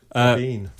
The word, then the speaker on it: Brabin: So it's pronounced Braben. – Okay, right. – Braben.